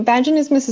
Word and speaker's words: vaginismus: Let's let's move on to vaginismus.